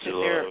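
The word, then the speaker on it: Vigillo: The thing though I'm wondering is, okay, they can give like somebody like what is it pronounced Vigilo or Vig? Is that how they pronounce Vigilo. – It? Vigilo.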